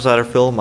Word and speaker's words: Satterfield: My name is Michael Satterfield. My last name is spelled S-A-T-T-E-R-F-I-E-L-D.